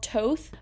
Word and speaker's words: Toth: And I would be kind of scared to pronounce it, but in their Instagram bio, it says, like, Toth pronounced as both, but with a T.